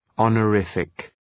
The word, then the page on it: honorific: ,ɒnə’rıfık